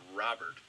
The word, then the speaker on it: Robert: There is confirmation that is pronounced Robert. – Robert is so much cooler. – I know, I'm a little disappointed, but it's Robert.